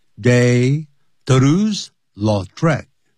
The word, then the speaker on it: de Toulouse-Lautrec: 所以以后把它翻成呢，这是一个形容词，就是叹为观止的，是这么叹为观止，如此的叹为观止 that the artist 这位的画家，他的名字叫做 Henry，如果是念成英文叫 Henry 啊，de Toulouse-Lautrec 啊，那可能是法文的这个念法呢，那不像英文英，这并不是所有的美国人他懂法文的、啊，加拿大人也一样。